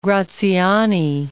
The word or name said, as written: Graziani